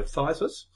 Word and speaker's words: phthisis: P-H-T-I-S. Actually, I think that phthisis, I, I think the P is silent. So phthisis, brought on by a gunshot wound through the right lung, which he said, I, detect a bit of, uh, you know.